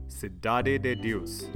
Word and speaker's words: Cidade de Deus: This one is a highly rated Brazilian crime film called City of God, or Cidade de Deus, if I may. And yes, I had to Google the Portuguese pronunciation of that.